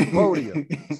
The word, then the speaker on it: Poitier: Fuck that, not Poitier. It was Poitier. Sidney Poitier, goddamn. Not Sidney Poitier. You hear people pronounce it that way? Sidney Poitier. I'm like, come on now. You got to add no French on to it.